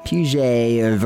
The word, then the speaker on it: Piaget: Welcome to the battle of Piaget versus Vygotsky. Jean Piaget. It's like French sounding. It's spelled like P-I-A-G-E-T. Piaget. Piaget, if you're trying to go real American on me.